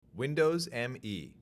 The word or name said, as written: Windows ME